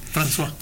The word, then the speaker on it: François: Una francesa, que era Francois, François, no sé cómo se dice. – François.